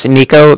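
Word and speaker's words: Niko: So yeah, my name's Nico. That's N-I-K-O. And then the Bank of Cardiff is spelled C-A-R-D-I-F-F, as in Frank Frank.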